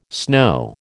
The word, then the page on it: snow: American English